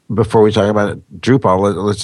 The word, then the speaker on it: Drupal: It's Drupal, like P-U-L-L. – Ah. – Oh. – Like, I mean, it's spelled P-A-L, but it's Drupal is how you pronounce it.